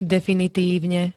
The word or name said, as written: definitívne